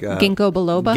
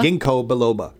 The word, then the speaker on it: Ginkgo biloba: Like Ginkgo Bilbo. Oh, like Ginkgo Biloba? – Ginkgo Biloba. Thank you.